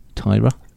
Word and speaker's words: Tyra: pronunciation Tyra, Tyra, Tyra. – Okay. – Is it uh, I'll allow it.